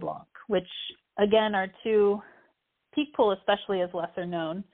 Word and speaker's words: Picpoul: Picpoul. Picpoul is how they pronounce it in France, but Picpoul is how we would say it here in the States. – Okay. – P-I-C-P-O-U-L.